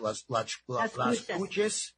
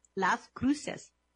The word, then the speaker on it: Las Cruces: It takes place in Las Cruces, which I think I said that right. Las Cruces. – No. – Anyway, how do you say that? – Las Cruces. – Las Cruces. Okay, there you go.